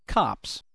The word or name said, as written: cops